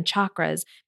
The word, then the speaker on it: chakra: So first of all, let me give you the right pronunciation of it. It's called chakra. Mm-hmm. It's not chakra or it's not chakra. And singular is chakra and plural is also chakra.